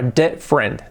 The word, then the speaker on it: different: Y luego dice, and in a different way, cuidado, different, esta palabra, different, dos sílabas,